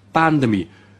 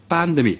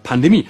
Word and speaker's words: Pandemie: Betonung bei Lauterbach immer auf der ersten Silbe. Pandemie. Wie bei WC. Auch erste Silbe. Pandemie. Nur Dilettanten sprechen von der Pandemie.